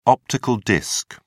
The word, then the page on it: optical disc: British English